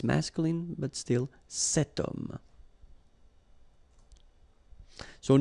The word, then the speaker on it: homme: Remember, ash is not pronounced, okay, so you've got the sound of o at the beginning of this word, okay, cet homme.